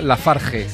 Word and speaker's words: LaFarge: En fin, para que lo quiera buscar, Pokey, como si fuera póker, y acabado en Y, Lafarge, se escribe con G.